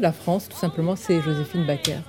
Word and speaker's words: Joséphine Baker: Ma France est Joséphine Baker, pour le prononcer en français.